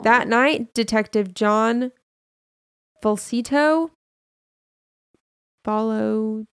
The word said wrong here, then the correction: Falatico: I pronounced that so wrong and I apologize. Detective John Falatico. Falatico. I think that's right.